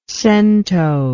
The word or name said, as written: centonization